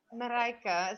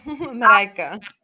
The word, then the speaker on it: Marijke: Marijke. It's like, it rhymes with Jamaica. Marijke.